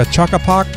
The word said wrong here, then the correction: chock-a-block: What do you call it? – Chock-a-block full of tech.